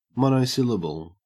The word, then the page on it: monosyllable: Australian English